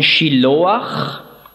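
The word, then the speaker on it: Shiloach (שילוח): Siloah, hebräisch ausgesprochen Shiloach.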